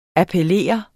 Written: appellere

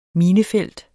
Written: minefelt

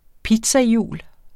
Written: pizzahjul

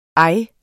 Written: ej

